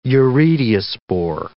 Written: urediospore

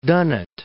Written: Dunnet Head

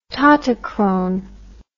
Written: tautochrone

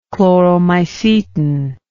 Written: chloromycetin

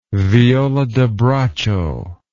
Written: viola da braccio

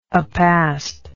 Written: apast

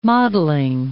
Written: mottling

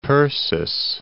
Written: Persis

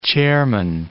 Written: chairman

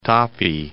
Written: Tapi

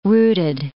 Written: rooted

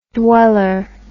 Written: dweller

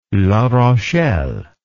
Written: La Rochelle

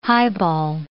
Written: highball

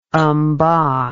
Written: mba

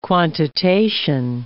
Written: quantitation

